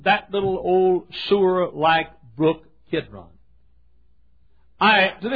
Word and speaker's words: Kidron: You'd say maybe Kidron, K-I-D-R-O-N. Some folks spell it K-E-D-R-O-N.